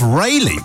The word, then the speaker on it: Rayleigh: the Essex town of Rayleigh, which rhymes with Cayley,